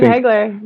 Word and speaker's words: Hagler: Yeah, probably Marvin Hagelin or Marvin Hagler. I can't remember fucking how to pronounce his last name. – Yeah, Marvin Hagler.